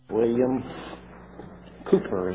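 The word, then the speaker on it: William Cowper: William Cooper is how you pronounce his name.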